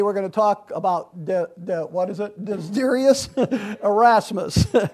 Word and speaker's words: Desiderius: Desiderius.